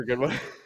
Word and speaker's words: Goodwin: I think Jennifer Goodwin is spelled with two O's. – I know. – Okay. – I just, but like Goodwin or Godwin, like I pronounce it like it's got all the same letters. So I go Godwin.